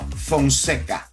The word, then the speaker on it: Fonseca: All right, if you want to get connected, Julio Fonseca, am I saying it right? Francesca? – No, Fonseca. – Fonseca. Yeah. Sorry about it.